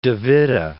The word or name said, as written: Witte, de